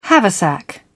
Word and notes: haversack: British English

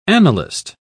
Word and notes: annalist: British English

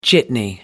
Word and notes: jitney: American English

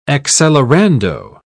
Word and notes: accelerando: British English

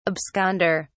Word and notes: absconder: British English

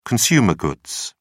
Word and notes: consumer goods: British English

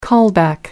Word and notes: callback: American English

